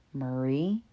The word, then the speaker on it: Murray: Chad Michael Murray. I said it correctly, not like how my bougie self wanted to say it because I thought Murray was a tacky name. I used to say Murray, as discussed in the Aughts podcast.